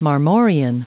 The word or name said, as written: marmorean